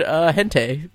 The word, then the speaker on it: hentai: Some of us can rattle off names for weird hentai movies. – Did I pronounce that right, Tyler? Hentai? – It's perfect.